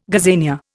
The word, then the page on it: gazania: Indian